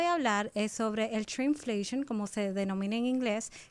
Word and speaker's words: shrinkflation: ¿Cómo se pronuncia lo otro? Crickflation. – Shrinkflation. Oye eso, Dios santo, todos los días uno aprende algo nuevo.